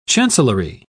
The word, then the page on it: chancellory: British English